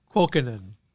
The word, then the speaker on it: Kuokkanen: Kuokkanen.